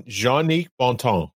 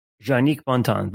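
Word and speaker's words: Jongnic Bontemps: you have to help me with the pronunciation again on this one. – Jean-Nic Bonton. – Jean-Nic Bonton. Thank you.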